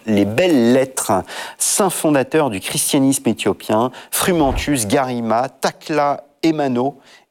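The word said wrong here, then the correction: Takla Haymanot: et Evostateos. – Tekla Aymanot et Eostateos.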